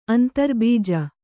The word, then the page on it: ಅಂತರ್ಬೀಜ: Kannada